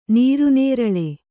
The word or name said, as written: ನೀರುನೇರಳೆ